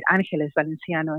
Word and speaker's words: Ángeles Valenciano: I love that I can now pronounce my name in Spanish, right? Ángeles Valenciano, and that's beautiful.